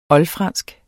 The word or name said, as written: oldfransk